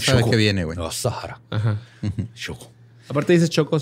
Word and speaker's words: Asahara: He pronunciado su nombre diferente cada vez. Es pues, Choco a Sahara, ¿no? Choco, sí, pero a veces digo a Sahara y a, pero es a Sahara.